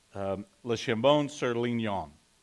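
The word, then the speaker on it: Le Chambon-sur-Lignon: Le Chambon sur Lignon, L-I-G-N-O-N.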